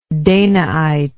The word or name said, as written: danaite